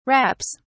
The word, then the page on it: wraps: British English